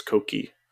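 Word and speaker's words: Cocchi: It's not even pronounced Kochi, it's Kochi.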